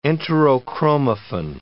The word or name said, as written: enterochromaffin